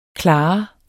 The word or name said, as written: klar